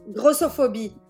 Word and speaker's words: grossophobie: Yeah. Grossophobie. Because gros means fat in French.